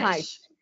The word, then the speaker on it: Reich: Reich, o nome. William Reich. Reich.